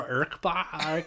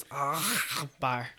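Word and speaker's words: Ackbar: Admiral Erkbar. – You're pronouncing it wrong, okay? It's Erkbar.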